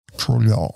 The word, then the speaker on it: Trudeau: Trudeau. I think you're supposed to say his name like you have marbles in your mouth. – Trudeau.